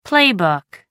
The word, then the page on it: playbook: American English